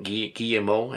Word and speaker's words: Guillemot: Guillemot, I think. I don't think you pronounce the T.